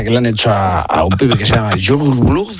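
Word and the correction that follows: Yungblud: Es que era muy difícil de pronunciar, pero luego ¿cómo se pronuncia? – Youngblood.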